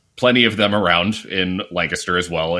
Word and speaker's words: Lancaster: Lancaster, for those of you listening who know, looks like it should be pronounced Lancaster, but it's pronounced Lancaster.